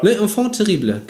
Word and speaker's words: Les Enfants Terribles: Und zwar gab es in den alten Spielen oder in den alten Teilen das Projekt Les Enfants Terribles. Richtig? Richtig ausgesprochen? Le Französisch? – Terrible, ja. – Les Enfants Terribles, genau.